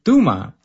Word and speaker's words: Touma: Well, that sounds like the perfect pronunciation in Spanish, but I'll let you know, my last name is from Palestine, so it's actually pronounced Touma.